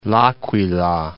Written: L'Aquila

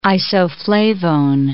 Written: isoflavone

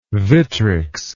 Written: vitrics

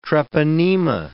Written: treponema

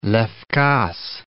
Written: Levkas

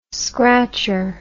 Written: scratcher

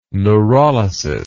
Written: neurolysis

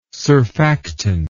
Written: surfactant

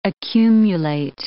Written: accumulate

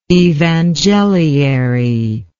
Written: evangeliary

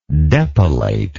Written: depilator

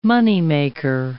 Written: moneymaker

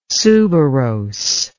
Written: suberose

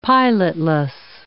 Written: pilotless

